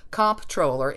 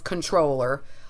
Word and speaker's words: comptroller: and which is why everyone says comptroller incorrectly. The thing that fascinated Ross and me when we were doing research for You're Saying It Wrong